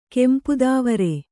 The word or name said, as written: ಕೆಂಪುದಾವರೆ